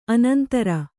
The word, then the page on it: ಅನಂತರ: anantara